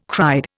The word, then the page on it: cried: Indian